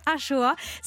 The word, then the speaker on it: axoa: Mais pas que, puisque c'est aussi celle d'un plat que l'on mange aujourd'hui dans tout le Pays Basque, la Ça s'écrit A-X-O-A. Eh Exactement. Et ça se prononce Achoa.